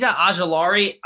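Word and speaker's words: Ojulari: I don't know if you can pronounce his name better than Aziz, I always mess this one up. Ajulari, I think. – Yeah, Aziz Ajulari, he's been in the mix a lot.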